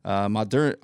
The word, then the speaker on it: Moderna: The other big pharma, or one of the other three, I guess there's the big three, Moderna, how do you say it? It's Modern with an A, Moderna, I guess, announces that its COVID-19 vaccine appears to be 94.5% effective.